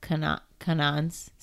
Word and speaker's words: Kwannon: But she had some of Kanan's, is that how you say it? – Yep.